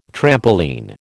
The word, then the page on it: trampoline: American English